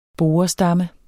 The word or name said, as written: borestamme